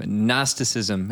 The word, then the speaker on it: Gnosticism: So let's start talking about this big, nasty, I almost said gnarly term, Gnosticism. – Well, gnarly I gotta have a silent G in there.